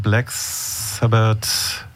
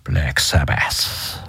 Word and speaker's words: Black Sabbath: Iron Man, Black Sabbath. Nee, wird anders ausgesprochen. – Black Sabbath.